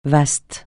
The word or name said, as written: vaste